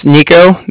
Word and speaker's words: Niko: Okay, my name is Nico. That's spelled N-I-K-O.